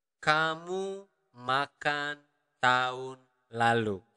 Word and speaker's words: tahun: We usually do not really pronounce the letter H. So we do not really pronounce it as tahun, but tahun.